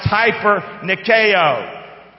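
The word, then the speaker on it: hyper-Nikeo (ὑπερνικάω): It's actually pronounced hyper-Nikeo. Okay? Hyper-Nike. We're going to bring it south.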